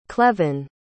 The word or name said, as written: clevon